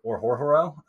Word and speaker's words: Orhorhoro: His name is Rook Orhorhoro. It's a mouthful, and it's spelled exactly how I pronounced it.